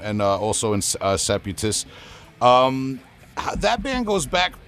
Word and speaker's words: Seputus: Uh, Seputus, did I say that right? – Yeah, Seputus. Yeah.